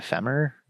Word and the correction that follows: Fremen: The Fremen.